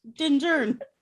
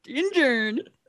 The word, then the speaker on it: Din Djarin: Din Djarin. – Din Djarin.